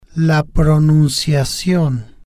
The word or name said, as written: la pronunciacion